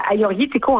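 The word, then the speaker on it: Agiorgitiko: Vous êtes très bonne, parce que pour ceux qui nous écoutent, le Aioritiko, en fait, c'est deux G, mais les G, on les prononce comme les Y.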